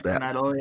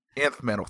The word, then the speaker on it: Nth Metal: It basically boils down to the Nth Metal or Ninth Metal. I don't know how you're supposed to pronounce Nth Metal, yeah. – Nth Metal, thank you.